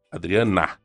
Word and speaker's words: Adriana: Senhores, nós estamos conversando com a doutora Adriane é, Adriane, né? – Adriana. – Adriana. Uhum. Eu sabia que nós estávamos falando errado.